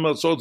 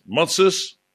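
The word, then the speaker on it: Matzot (מצות): Matzot, dat wordt door de Oost-Europese Joden uitgesproken als Matzes. Die zeggen ook niet Shabbat, maar die zeggen Shabbos. Dus Matzes kent u allemaal, dat woord. Maar Matzot is de naam zoals de Sephardische Joden het uitspreken en zoals het vandaag ook in Israël gezegd wordt.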